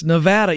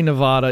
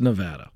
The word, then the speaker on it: Nevada: I got to tell you, i said it wrong enough to finally get it right. It's Nevada. Even though I want to say Nevada, it's Nevada.